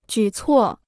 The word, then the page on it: 举措: jǔ cuò